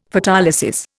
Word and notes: Photolysis: Indian

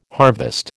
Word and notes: harvest: American English

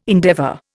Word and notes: endeavour: Indian

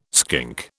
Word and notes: skink: British English

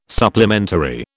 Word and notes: supplementary: British English

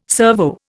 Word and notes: servo: Indian

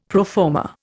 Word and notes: pro forma: Indian

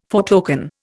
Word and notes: foretoken: Indian

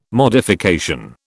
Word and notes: modification: British English

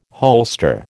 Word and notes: holster: American English

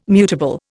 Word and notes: mutable: Indian

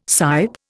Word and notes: sipe: Indian

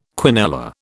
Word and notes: quinella: British English

